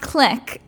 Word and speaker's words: clique: I think it's pronounced click.